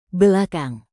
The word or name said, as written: belakang